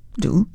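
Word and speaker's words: d'août: The English translation is The Doubts of August, and the month of August is D'AOUT, pronounced do, and the word doubt is pronounced do, so it's les doudous.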